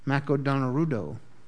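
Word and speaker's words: Mako Donarudo (マクドナルド): You know how they say it in Japanese? Mako Donarudo. That's how you say it. Say that with me. Mako Donarudo. That's how you say McDonald's in Japanese.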